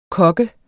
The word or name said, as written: kogger